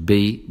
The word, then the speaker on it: ve: Remember that the V, we pronounce it be, be in Spanish, so el profesor me ve, from the verb ver, to see.